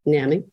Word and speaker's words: NAMI: There's something called National Alliance Mental Illness, NAMI, NAMI or NAMI, depending on who, you know, your preferred pronunciation. National Association. – NAMI.